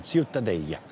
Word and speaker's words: Ciutadella: Aber Ciutadella, richtig ausgesprochen? – Ja, das Städtchen, Ciutadella.